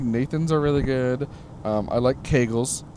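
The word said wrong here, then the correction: Koegel: I'm pretty sure that's not how it's pronounced. – That is not how it's pronounced. – Kegels hot dogs. – Kogel. – Kogel. Thank you.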